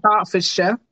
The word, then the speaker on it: Hertfordshire: Yeah, I had a little giggle there because you said Hertfordshire. We say Hertfordshire. So it's just in the pronunciation.